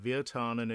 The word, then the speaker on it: Wirtanen: Wirtanen, is it? 46P Wirtanen. It sounds very Finnish to me, I have to say. So Wirtanen it would be.